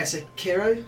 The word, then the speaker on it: Sekiro: Is it Sekiro? Is that how you say it? – Yeah, Sekiro. – Yeah. – It is.